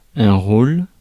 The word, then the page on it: roll: American English; [ɹoʊl]; /ɹoʊl/